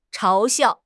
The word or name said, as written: 嘲笑